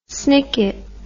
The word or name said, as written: snicket